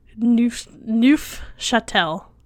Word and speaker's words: Neufchâtel: It's spelled Neufcha with a little carrot on top, T-E-L. Neufchâtel cheese, and it is not Pennsylvania Dutch.